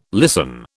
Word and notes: listen: British English

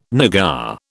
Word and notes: nougat: British English

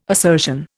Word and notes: assertion: Indian

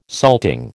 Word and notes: salting: American English

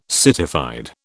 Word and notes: citified: British English

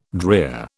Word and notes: drear: British English

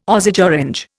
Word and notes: Osage orange: Indian

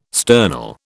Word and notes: sternal: British English

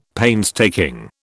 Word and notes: painstaking: British English

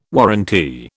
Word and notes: warrantee: British English

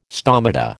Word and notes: stomata: American English

